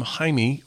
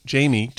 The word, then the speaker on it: Jaime: Brittany Howard, Stay High, a single, or it could have been from her album Jaime, or Jamie, depending on how you pronounce it.